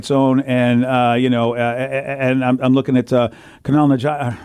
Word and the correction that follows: Kumail Nanjiani: I can never say his name. – Kumail Nanjiani. – That's the guy.